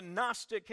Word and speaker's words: Gnostic: It's called the Gnostic heresy, Gnostic with a silent G on the front of that word.